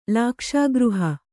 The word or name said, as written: ಲಾಕ್ಷಾಗೃಹ